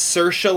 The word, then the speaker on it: Saoirse: And in her opening monologue, she said it's Saoirse like inertia. So there you go. It's Saoirse Ronan.